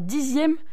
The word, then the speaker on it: dixième: Alors que l'on dit une dizaine avec un Z et on écrit un dixième que l'on écrit X mais qu'on prononce Z.